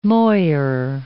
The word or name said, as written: moire